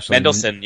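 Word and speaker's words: Mendelsohn: And, with, how do you say his last name? Mendelssohn? – Mendelssohn, yeah.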